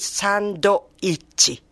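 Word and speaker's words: sando-ichi (サンドイッチ): All the Japanese pronunciation, consonant with vowel ending. – I see, right. – That's why, for example, sandwiches, sando-ichi.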